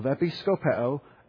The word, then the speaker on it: episkopeo: And it's the word for episkopeo. Episkopeo. E-P-I-S-K-O-P-E-O. Long O. Episkopeo.